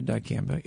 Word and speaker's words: dicamba: What, yes, what is dicamba? Is that am I even saying that correctly? – Yeah, dicamba.